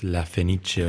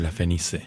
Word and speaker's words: La Fenice: No, acá también me da miedo a mí pronunciarlo, pero es La Fenice o La Fenice.